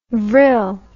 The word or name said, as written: vrille